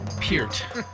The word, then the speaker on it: Peart: And what I would say was Neil Peart, but I would be saying that incorrectly. – Peart. Peart.